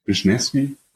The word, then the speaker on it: Wisniewski: Ja, es haben auch noch Chance Warmack gesigned und ähm, Steven Wisniewski, Wisniewski, ja. – ja. – Wisniewski auf Deutsch ausgesprochen, wie das dann auf Englisch geht, ist ein bisschen schwieriger.